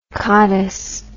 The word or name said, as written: cottus